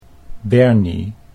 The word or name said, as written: Bernie